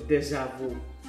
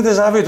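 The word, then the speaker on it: déjà vu: Τελικά πώ προφέρετε το ντεζαβού. – Εγώ πιστεύω ότι είναι ντεζαβί τώρα γιατί όλοι οι υπόλοιποι τον είναι ντεζαβού. – Ντεζαβί είναι. Στα γαλλικά είναι ντεζαβί.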